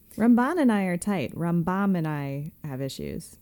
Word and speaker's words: Rambam: Rambam, emphasis on the first syllable when talking about Maimonides.